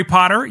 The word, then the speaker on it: Potter: My name is Jerry Potter. Yes, it rhymes with a boy wizard.